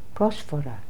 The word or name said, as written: prosphora